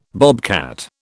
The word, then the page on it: bobcat: British English